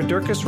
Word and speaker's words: Durkis: Stu Dolnick, Sarah Durkis, Rhymes with Circus,